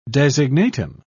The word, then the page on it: designatum: British English